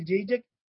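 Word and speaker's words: Çiçek: كتاب الإسلام تسمية أسماء السور من منظور حضاري لمحمد مروان جيجك، هذا يعني أو محمد خليل جيجك هو هكذا ينطق اسمه لأنه تركي، الكاف كأنها تنطق بجيجك، فهذا بحثه يفتح آفاقا واسعة في دلالات أسماء السور.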